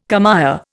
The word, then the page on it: commère: Indian